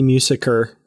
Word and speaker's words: Musiker: So this character, Raffi Musiker, and I hope I'm saying that correctly.